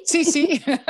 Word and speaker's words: Cici: Eh, zij is de founder, co-founder van Coco en Chichi. Zeg ik het goed? Sisi? Sisi. Coco en Sisi.